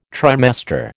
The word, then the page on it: trimester: American English